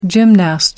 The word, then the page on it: gymnast: American English